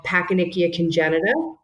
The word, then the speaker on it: Pachyonychia congenita: And they have gathered patients and their families with a rare disease called Pachyonychia congenita.